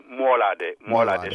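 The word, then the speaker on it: Moolaadé: Is it Mulade? – It's Mulade. Shot Mulade.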